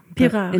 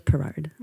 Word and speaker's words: Pirard: Pirard. In English, Pirard.